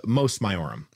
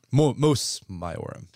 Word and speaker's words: Mos Maiorum: Moris Maiorum? – Yeah, Mos Maiorum. – Mos Maiorum.